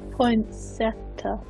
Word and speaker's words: poinsettia: I'm going to go with a poinsettia. – Oh, fantastic. You pronounced it, so I didn't have to. Yes, correct.